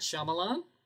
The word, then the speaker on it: Shyamalan: So I've recently discovered it's M. Night Shyamalan. is how it's pronounced.